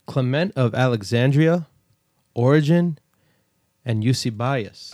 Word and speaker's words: Eusebius: Eusebius. – Eusebius.